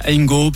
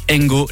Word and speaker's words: Hango: Et ça s'écrit H-A-N-G-O. – C'est juste. Ça, comme Hangout ou Hangover, chacun son. – Merci beaucoup, Edouard. Merci. Enzi. Donc, Engo, l'application qu'il vous faut, avec évidemment des rabais.